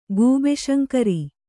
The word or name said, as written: ಗೂಬೆಶಂಕರ